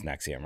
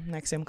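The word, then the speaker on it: NXIVM: It's pronounced Nexium, right? – Yeah, Nexium. Cool.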